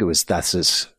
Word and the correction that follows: Kessis: I think it's, I think he pronounced it, like we called him Ted, Kessis, thank you.